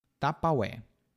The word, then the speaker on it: Tupperware: Tapaué. Essa é a pronúncia brasileira de Tupperware.